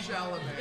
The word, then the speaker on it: Chalamet: Oh, Chalamet? – Chalamet.